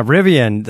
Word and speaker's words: Rivian: There's also the Rivian. Am I saying that right? – Yeah, Rivian.